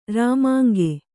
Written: ರಾಮಾಂಗೆ

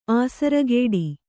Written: ಆಸರಗೇಡಿ

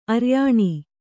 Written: ಆರ್ಯಾಣಿ

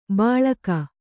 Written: ಬಳಗ

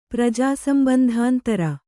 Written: ಪ್ರಜಾಸಂಬಂಧಾಂತರ

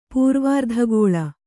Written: ಪೂರ್ವಾರ್ಧಗೋಳ

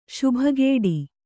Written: ಶುಭಗೇಡಿ

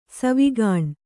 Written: ಸಾವುಗಾಣ್

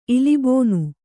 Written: ಇಲಿಬೋನು

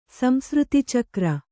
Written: ಸಂಸೃತಿಚಕ್ರ

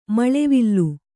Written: ಮಳೆವಿಲ್ಲು

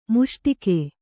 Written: ಮುಸುಡಿಕ್ಕು

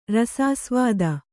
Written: ರಸಾಸ್ವಾದ

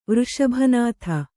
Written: ವೃಷಭನಾಥ

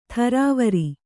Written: ಥರಾವರಿ

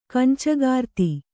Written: ಕಂಚಗಾರ್ತಿ

